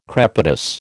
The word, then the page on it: crepitus: American English